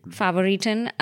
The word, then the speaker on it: Favoriten: Favoriten.